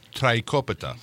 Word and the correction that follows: Tiropita: Tiropita? – Tiropita, which means three cheeses, right?